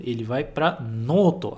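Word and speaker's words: noto (ノート): E não se esquece de pronunciar esse noto, de prolongar. Noto, ok? Noto. É diferente de noto. Noto, noto. Tudo bem? Em japonês isso faz diferença.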